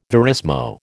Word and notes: verismo: American English

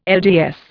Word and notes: LDS: Indian

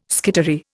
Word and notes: skittery: Indian

